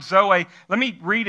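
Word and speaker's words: Zoe (ζωή): And that word life in the Greek is the word zoe. Why don't you say that out loud with me? Zoe. Zoe. So we would pronounce it Zoe. Some of you, maybe you've named your daughter Zoe. It's that same word, Z-O-E.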